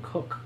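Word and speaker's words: Greg Koch: But Greg Cook, I think you actually say his name Cook. – Kosh? – Yeah, K-O-C-H. – Cock? – Cook.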